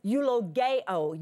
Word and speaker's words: eulogeo: All of those words come from the same basic Greek word, eulogeo, eulogeo.